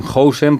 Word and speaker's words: Huijsen: Pues en el cómo pronunciar, que buscamos todos en neerlandés, dicen Huijsen, porque Huis sería el equivalente al house británico, es decir, casa, y el sen es el equivalente al son, al hijo.